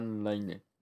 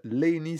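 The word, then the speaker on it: Layne: Ou Lane Stanley. Ça dépend comment on prononce, ouais. Mais je pense qu'on dit Laney. – Laney, ouais. – Je sais pas comment tu prononcerais ça, toi. Lane ? – Oui, Lane, ouais. Lane, ouais.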